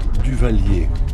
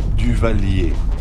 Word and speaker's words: Duvallier: Duvalier. Duvalier. – How do you spell that? – D-U- V-A-L-L-I-E-R.